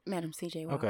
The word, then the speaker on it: Madam C.J. Walker: Madam C.J. Walker.